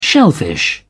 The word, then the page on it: shellfish: British English